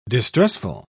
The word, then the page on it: distressful: British English